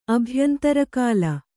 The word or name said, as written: ಅಭ್ಯಂತರಕಾಲ